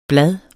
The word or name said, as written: blade